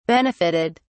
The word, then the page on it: benefitted: British English